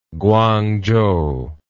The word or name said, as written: kuangchou